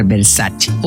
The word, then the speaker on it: Versace: Por ejemplo, Versace, cuando la marca Versace es italiana. esto, se tiene que pronunciar Versace. O